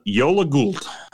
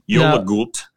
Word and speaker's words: Jólagult: This is called Jólagult. It's spelled Jólagul, like a seagull. Yola, I believe, refers to the Yule season, the Christmas season. Jólagult.